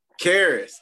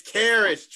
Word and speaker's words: Caris: Yeah, Karis, it's Karis. – Karis, okay, Karis, okay. – Karis LeVert. – So how you spell it? C-A-R-I-S? – No, C-A-R-V-I-S.